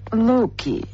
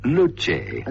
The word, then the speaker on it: Luce: This model is spelt L-U-C-E. How would you pronounce it? – Luki. Mazda Luki? – No, in fact, it's pronounced Luce. – Luce? It rhymes with I want to be alone, hey? – The new Mazda Luce.